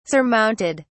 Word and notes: surmounted: British English

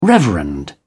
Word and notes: reverend: British English

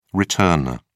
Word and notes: returner: British English

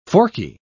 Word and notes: forky: British English

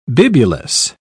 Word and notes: bibulous: British English

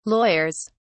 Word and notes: lawyers: British English

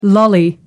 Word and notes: lolly: American English

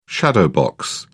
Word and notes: shadowbox: British English

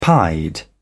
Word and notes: pied: British English

